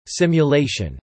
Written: simulation

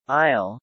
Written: aisle

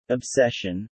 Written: obsession

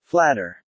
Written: flatter

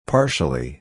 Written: partially

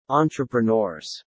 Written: entrepreneurs

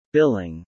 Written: billing

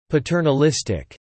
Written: paternalistic

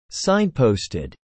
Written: signposted

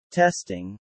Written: testing

